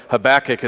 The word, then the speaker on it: Habakkuk: Habakkuk is the way I've heard it the most. We don't know exactly how to pronounce it, so that will be the way we go forward here together.